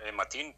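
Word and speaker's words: Mateen: Eh, esta, de hecho, esta mañana, este señor Matín, tú lo has pronunciado bien, nació en los Estados Unidos, era un ciudadano estadounidense de nacimiento.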